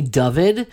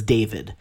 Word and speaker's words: Dovid: Most people, like my mother, my father, they call me Dovid. But when I feel like, you know, there's going to be a lot of creative license and I just want to go with the good old-fashioned, easy to pronunciate, I usually introduce myself as David.